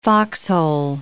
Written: foxhole

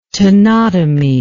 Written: tenotomy

